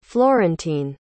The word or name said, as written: florentine